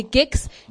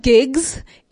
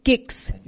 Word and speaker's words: Giks: I'm your girl, Cynthia, but you know you can call me Gix. Gix is spelled G-I-K-S. A lot of people have been asking me, is it Gigs? As in, you know, no, it's not Gigs. It's Gix. G-I-K-S.